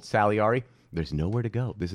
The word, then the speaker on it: Salieri: Salieri.